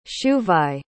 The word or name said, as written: shuvai